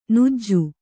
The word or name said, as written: ಞ